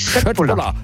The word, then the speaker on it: Köttbullar: Ja, Köttbullar. – Ja! Oder wenn man es ganz korrekt aussprechen, ich habe mir sagen lassen, das K wird in Schweden wie ein Sch ausgesprochen. Also wenn es Köttbullar, hat es doch geklappt.